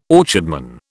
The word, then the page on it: orchardman: British English